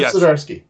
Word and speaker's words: Zdarsky: How do you say the guy? Chip Zdarsky, yes.